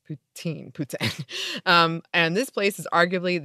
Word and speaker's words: poutine: First, in French, or at least Quebec French, the word poutine is pronounced the same as the ruler poutin.